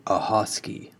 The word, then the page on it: Ahoskie: American English; North Carolina